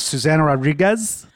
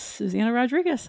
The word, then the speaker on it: Susana Rodriguez: Susana Rodriguez. Did I say it right? – Yes, Susana Rodriguez.